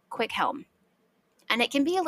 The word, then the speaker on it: Cwichelm: There is an Old English name that was very, very common, and it is pronounced Quickhelm. But it is, I'll tell you how it's spelled. C-W-I-C-H-E-L-M.